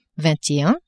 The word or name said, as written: vingt et un